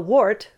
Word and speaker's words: WHART: Let's look at the first, because you attended a course, WHART, I don't know if that's how you pronounce it, W-H-A-R-T, Wildlife Human Attack Response Training. Whew, what was the purpose of this training? Whew. – Well, WART, which by its founder's admission, a terrible acronym,